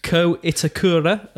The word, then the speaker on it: Ko Itakura: Ko Itakura, the Japanese player from... – Absolutely. And that was good pronunciation.